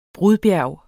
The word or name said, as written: BRUDBJERG